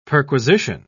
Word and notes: perquisition: British English

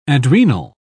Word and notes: adrenal: British English

